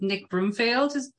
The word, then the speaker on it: Nick Broomfield: And there's a moment where she's with Nick Broomfield.